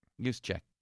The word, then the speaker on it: Juszczyk: Kyle Juszczyk. Is that how you say his name? – Juszczyk.